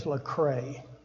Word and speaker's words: Lecrae: His name is Lecrae, an American Christian rapper, songwriter, record producer, and actor.